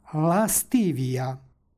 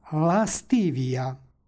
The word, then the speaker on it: lastivija: stihu pročitali smo lastivija. Na početku stoji sonant, iza njega je suglasnik, između njih se dodaje nepostojano a radi lakšeg izgovora.